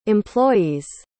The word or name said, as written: employee's